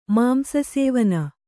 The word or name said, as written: ಮಾಂಸಸೇವನೆ